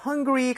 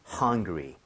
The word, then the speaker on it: hungry: 그렇기 때문에 우리 발음할 때 입모양 모으고 R 사운드를 제대로 하려면 입모양 모으고 혀를 당겨줘야 됩니다. So R이기 때문에 리가 아니라 re, re 이렇게 돼야 돼요. 자, 두 번 해볼게요. Hungry, hungry, hungry, hungry.